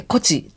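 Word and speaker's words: 東風: 東からの風、え東の風でこちと読みますけれども、こちよけの取って、これは今も残っています。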